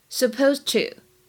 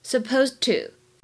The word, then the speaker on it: supposed to: 还有最后这句话当中的 supposed to 当中呢有一个完全失去爆破，我们应该读作是 supposed to, supposed to。